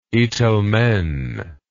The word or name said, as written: itelmen